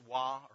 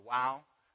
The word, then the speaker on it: Vav: And so if we were going to transliterate this, what we have is the Yod, He, Vav, He. Some people pronounce this Wah or Wow.